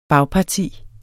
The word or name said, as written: bagparti